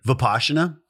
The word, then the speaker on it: Vipassana: Vipassana.